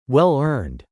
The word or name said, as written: well-earned